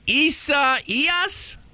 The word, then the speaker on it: Isaias: Hurricane Isaias? – That's pretty good. Well, I'd say a little more Y in the middle. So Isaias. Yeah. – Isaias. Okay. – Isaias. There you go. – E-I-E-I-O, kind of an Isaias.